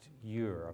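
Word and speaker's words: Győr: So I'm going to a town in Hungary pronounced you're.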